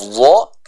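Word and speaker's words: dha (ظ): Huruf dha kita baca dengan tebal, ya. Secara makhraj huruf dha ini sama pengucapannya dengan huruf dzal dan huruf tsa, yaitu apa? Ujung lidah kita bertemu dengan ujung gigi seri bagian atas.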